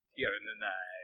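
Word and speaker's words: Tír na nÓg: And we're watching Mystic Knights of Tir-na-nog. – Tir-na-nog. – You know, like eggnog, but with knights and armor and fire. – It's like eggnog. – They occasionally pronounce the G. Depends on who's speaking.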